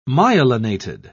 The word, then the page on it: myelinated: British English